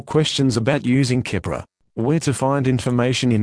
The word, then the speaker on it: Keppra: This is the consumer medicine information for Keppra Oral Solution and Tablets, pronounced Keppra. Active ingredient, levetiracetam, pronounced levetiracetam.